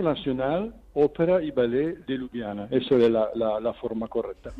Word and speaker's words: Ljubljana: Ayúdame a decirlo, por favor. Lo voy a decir mal. Ljubljana, ¿cómo se dice? – Es la Ópera y Ballet Nacional de Ljubljana.